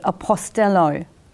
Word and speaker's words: apostello: An apostle is one who was sent, from the Greek word, verb, apostello. I hope I said that right. – That's right.